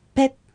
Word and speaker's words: ペッ: 続いてペッペレ、川という意味です。 – サッケと同じように T の発音に注意しながら発音してみてください。舌先が歯の裏についていることを確認するようにしましょう。